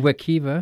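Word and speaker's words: Wekiva: how do you say that, Wakiva? – Wakiva.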